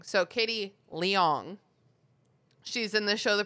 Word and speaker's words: Leung: What, L-I-A-N-G? – It is L-E-U-N-G.